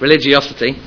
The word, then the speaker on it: religiosity: For all their, I can't say this word, for all their religiosity, how do you say it? Religiosity, thank you.